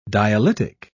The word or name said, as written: dialytic